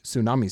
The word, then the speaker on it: tsunami: Suwadi? Nakora says. Looks down at Petra, who simply shrugs. Suwadi again, Chance? I believe it is pronounced tsunami, sir.